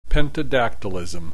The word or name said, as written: pentadactylism